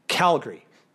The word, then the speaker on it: Calgary: So it's not Calgary, it's not Calgary, it's Calgary. So think of it as a G-R-E-E at the end.